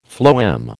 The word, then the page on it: phloem: American English